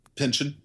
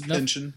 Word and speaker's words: Pynchon: Pynchon. – Pynchon? Good enough. – Just Pynchon.